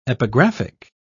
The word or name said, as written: epigraphic